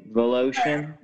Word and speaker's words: Volotion: It's nothing to do with your middle last name, or your last name, Volotion? – Volotion, yeah. – Okay. Water, ocean. – It does rhyme with the ocean, but I do love the ocean as well.